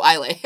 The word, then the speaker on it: Islay: Single malt whiskey from the Isle of Islay, Lagavulin, which is my favorite. – The distillery. – Isle of Island? Oh, Islay. Or Islay. Yeah, I know Isle.